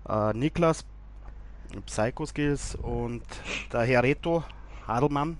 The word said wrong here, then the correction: Haldemann: glaube ich, ist richtig ausgesprochen. Haldemann. Haldemann.